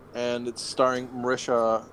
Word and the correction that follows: Mahershala Ali: Mahershala Ali.